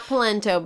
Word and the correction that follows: Polanco: but Polanco. – Polanco. Right? – Yeah.